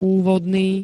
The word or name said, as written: úvodný